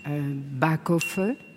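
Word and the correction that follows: Baeckeoffe: C'est pas tout à fait. C'est presque ça. Ça ne se prononce pas exactement comme ça. – Bake off. – Bake off, oui, c'est ça. Le bake off. Alors, c'est vrai que. Ça s'écrit B-A-E-C-K-E-O-2-F-E. Ouais. Et je crois qu'en Alsace, on dit Bekehoff, mais encore, je ne suis pas absolument certain de prononcer parfaitement ce mot alsacien.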